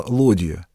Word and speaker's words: лодья: И были транспортные суда, крупные суда, среди которых самым большим была лодья. Именно вот с таким ударением это судно называют на севере.